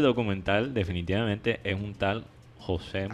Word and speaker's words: José: Que José es la manera como decirlo en, en, en, portugués.